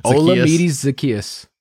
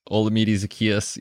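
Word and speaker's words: Olamide Zaccheaus: Russell Gage, uh, I don't even know how to say the guy's first name. – Olamide Zacchaeus. – Olamide Zacchaeus.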